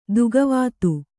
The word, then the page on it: ದುಃಖವಾದ: duhkha vāda